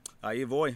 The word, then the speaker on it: Ahivoy: Ahivoy. – And I said it right this time. Right? – Correct.